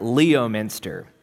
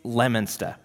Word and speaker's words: Leominster: Or another town called what looks like Leominster. It's Leominster.